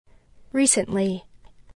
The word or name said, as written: recently